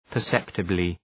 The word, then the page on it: perceptibly: pər’septəblı